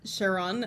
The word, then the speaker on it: Charon: The whole vibe he's given off is like uh Charon, I think is how it's pronounced from like Greek mythology.